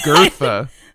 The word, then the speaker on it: Goethe: Yeah, you idiot, it's pronounced Goethe. – How dare you? No, apparently that O-E sound is something that does not exist in American language, based American English, and so we're all bad at it, because...